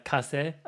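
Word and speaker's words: Casé: Like, Regina Casse, or Regina Case, if you're looking at it phonetically, if you're wondering who I'm talking about, is unbelievable.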